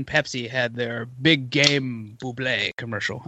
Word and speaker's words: Bublé: He eventually, like, he kept trying to tell people it's pronounced Bublé.